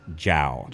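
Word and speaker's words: João: So I corrected him that, well, you know, actually the pronunciation is just Jao. – Jao, right. – And that came from experience of other players in the league. – Okay. – And the pronunciation guides always just J-O-W, Jao.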